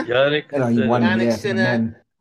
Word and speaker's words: Jannik Sinner: Jannik Sinner and Arina Sabalenka, your two winners.